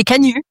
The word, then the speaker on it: Canuts: Which I thought, pardon, Canu, oui, they don't say the T, that's right. – We don't say the T, exactly. This is France. We don't pronounce the last consonant.